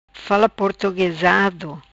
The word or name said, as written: fala portuguesado